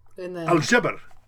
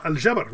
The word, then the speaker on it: Al-Jabr: Al-Jabr. – Ah, Al-Jabr, right there. – Al-Jabr. – Yeah, Al-Jabr. – Ah. It's spelled A-L-J-A-B-R, so it throws me, you know? – Mm-hmm.